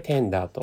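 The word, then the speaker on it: TENDRE: そんなあえてそこを逆にしていてる、えー、遊び心を持たしてテンダーと読む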